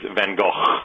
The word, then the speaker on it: Van Gogh: the Dutch pronunciation is Van Gogh.